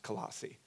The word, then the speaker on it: Colossae: The believers in Colossae, I'm going to say Colossae a few times accidentally. It's actually pronounced Colossae. It's hard to say, though, because it's A-E at the end.